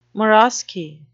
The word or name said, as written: marrowsky